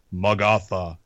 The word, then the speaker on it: Magatha: I learned that it's not pronounced Magatha. It's Magatha.